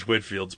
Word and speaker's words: Whitefield: I think that's supposed to be George Whitfield's preaching. That's how I've always heard his name pronounced, mm-hmm.